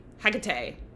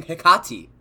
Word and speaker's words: Hecate: Oh, sorry, her name is Hecate, which is truly Hecate. Well, Hecate. – that's how you say it, but in this fucking episode, they kept saying Hecate.